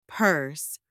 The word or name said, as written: purse